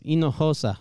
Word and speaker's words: Hinojosa: No, it's Hinojosa. – Hinojosa. Raul. – You don't pronounce the H, and the J sounds like an H, buddy.